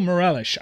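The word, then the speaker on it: Meireles: Raul Morelis, I'm trying to make you laugh because when's the last time you thought about this guy? I miss you. – And a tremendous Portuguese pronunciation on Morelis.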